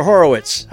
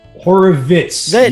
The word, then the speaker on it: Horovitz: And is it Horovitz or Horowitz? How do you pronounce it? – Horovitz. Yes, with a V. It's with the bane of our existence, getting called with a W.